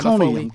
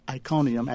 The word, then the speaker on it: Iconium: Iconium comes from the word icon, Iconium, and it's in the book of Acts, one of the cities that Paul was kicked out of, believe it or not.